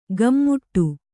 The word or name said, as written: ಗಮ್ಮುಟ್ಟು